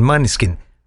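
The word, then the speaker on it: Måneskin: La otra opción que me daba el señor doctor era que pusiera el Maneskin. Maneskin, dije, ¿eh? Es Maneskin. Maneskin se pronuncia.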